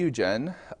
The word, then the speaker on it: Eugène: If you're looking for that on the internet, it's spelled like Eugene. But Marie Eugène, he was wonderful because he was a commentator on Carmelite spirituality, who himself was beatified.